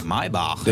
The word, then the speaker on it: Maybach: Maybach. – Oh, jij moet een beetje Duits uitspreken, ja.